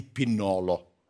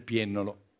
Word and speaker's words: Piennolo: Dico solo a Briatore, occhio quando pronunci i preziosi ingredienti della terra campana, perché un pomodorino di Upiennolo non lo puoi far diventare di Pinnolo. Sai di Pinnolo? Briatore sfida Sorbillo, ma sbaglia la pronuncia del pomodorino del Piennolo.